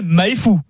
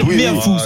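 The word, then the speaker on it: Meafou: L'arbitre me dit, il s'appelle Emmanuel Méafou. Il ne s'appelle pas Emmanuel Maïfou. Il s'appelle Méafou. C'est Pierrot qui a bien prononcé.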